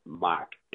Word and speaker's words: Mach: M-A-C-H. So it's still pronounced mocktail, but it's with our Detroit thumbprint on it. So it's mock, M-A-C-H, which I think is pretty cool because it's got that automotive relationship that is all Detroit.